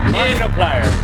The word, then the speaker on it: Knopfler: Knopfler. Mark Knopfler? – Knopfler.